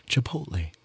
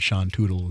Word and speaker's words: Chipotle: How to pronounce that, and then they say Chipotle. And the joke one said Sean Tootle is how you pronounce.